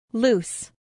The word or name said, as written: loose